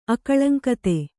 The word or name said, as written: ಅಕಳಂಕತೆ